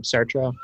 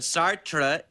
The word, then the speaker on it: Sartre: Pronounce this name, Sartre. – Camus can do, but Sartre is smartre.